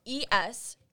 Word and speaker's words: espresso: Because the word, it's spelled Espresso. It's not espresso. It's espresso. Espresso.